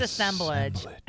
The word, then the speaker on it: assemblage: And then she transitions into these, let me know if I'm saying this right, Ensemblage. That's, God damn it. – Well, it's just so pretentious and ridiculous. It's assemblage.